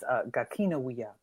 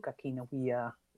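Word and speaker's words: gakina awiiya: Well, bonjour, gakini wia. I said that wrong. It's gakina wia. Bonjour, gakina wia.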